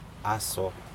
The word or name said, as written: aso